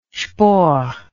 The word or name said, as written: spohr